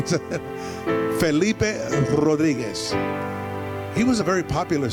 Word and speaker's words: Rodriguez: His name was Felipe Rodriguez. Can you say it like that? I didn't hear no rolling R. Say, Felipe? That's easy. Now try this. Say, Rodriguez.